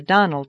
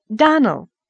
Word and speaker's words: Donnell: "I'm sorry," faltered Anne, vainly trying to recollect any incident of the morning connected with the Donnell children. "Clarice Almira told me that you pronounced our name Donnell. Now, Miss Shirley, the correct pronunciation of our name is Donnell. Accent on the last syllable.